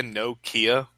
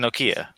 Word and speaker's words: Nokia: No, it's a Nokia. How do you say it? – Nokia. – Yeah, Nokia. – You said it like two words, though.